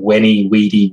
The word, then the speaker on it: Veni, Vidi, Vici: Like, for example, the letter V, like as in Viking, there's a school of thought that letter was actually pronounced with a W sound by the Romans. – Viking. – So Julius Caesar's famous Veni, Vidi, Vici, I came, I saw, I conquered, would actually have been Weni, Weedy, Weeky, which sounds quite as good.